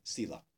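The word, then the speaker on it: sīla: And in Sanskrit, it just has a stroke over the S, which is a sh. So, shila or sila.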